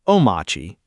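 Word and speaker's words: Omarchy: It's called Omarchi Quattro, pronounced Omachi by its creator.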